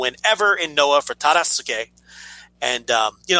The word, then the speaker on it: Tadasuke: Tadasuke, right? Tadasuke. That's how you say it.